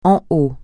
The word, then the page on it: en haut: français